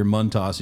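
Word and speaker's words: Montás: If you hear Montas and you think we're pronouncing incorrectly, it's actually the correct pronunciation of his name.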